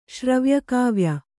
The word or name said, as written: ಶ್ರವ್ಯಕಾವ್ಯ